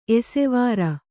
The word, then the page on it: ಏಸುವಾರ್: ēsuvār